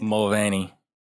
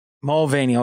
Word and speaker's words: Mulvaney: Dylan Mulvaney. Is that how you say it? – Mulvaney. – Mulvaney.